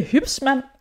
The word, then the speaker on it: Hybsmann: I dag der har jeg besøg af Mie Hybsmann. Var det rigtigt udtalt? – Det var, det var meget tæt på. Det var meget det, øh, perfekt.